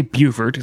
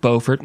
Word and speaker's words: Beaufort: And so we say Beaufort, it's spelled Beaufort, but we got yelled at by some hillbilly from South Carolina from our Robert Smalls episode, where he was from Beaufort, South Carolina. I don't know. It's spelled Beaufort, maybe in North Carolina, which is the vastly superior Carolina. You would say Beaufort like a normal human.